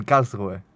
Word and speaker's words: Karlsruhe: En Karlsruhe.